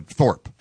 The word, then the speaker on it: Thorpe: Is he a Thorpe or Thorpe? – There's an E on the end, but they pronounce it Thorpe.